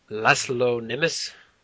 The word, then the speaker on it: László Németh: And this episode, we are going to talk about the 2015 trauma movie from Hungary, from the director László Németh.